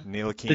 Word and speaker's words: Ntilikina: Ntilikina. The T is silent. A lot of eyes.